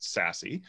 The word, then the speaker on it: SASE: which is pronounced SASE.